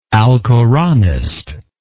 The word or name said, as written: alcoranist